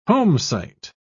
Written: homesite

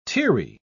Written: teary

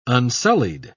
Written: unsullied

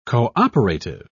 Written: cooperative

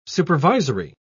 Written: supervisory

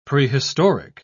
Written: prehistoric

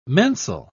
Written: mensal

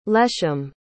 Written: leshem

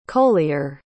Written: colyer

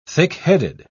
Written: thickheaded

